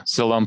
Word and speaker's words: Siloam: And I'm currently looking at an aerial view of the city of David from the, am I going to pronounce this right? Siloam Pool. Is that right? – Yeah. Siloam Pool.